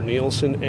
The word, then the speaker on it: Nielsen: C-H-A-N-D-L-E-R. – I'm Sergeant Robert Nielsen, N-I-E-L-S-E-N.